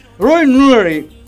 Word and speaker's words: Roy Neary: Yo te lo pronuncio en inglés. – Ajá. – ¿Cómo? Roy Neary.